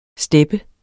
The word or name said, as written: steppe